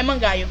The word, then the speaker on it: Mangaio: Mas eu, eu acho que eu falei certo, Mangaio, Mangaio. – É Mangaio.